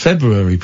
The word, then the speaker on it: February: I'm the only person I know that pronounces February properly. – February. – Yeah, with the R. I pronounce the R because February. – It's there. February.